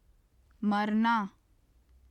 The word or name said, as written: marna